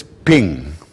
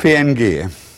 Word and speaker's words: PNG: Dieses PNG, wie spricht man das? Man spricht es Ping. Jeder sagt Ping und keiner sagt PNG.